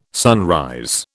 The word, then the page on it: sunrise: British English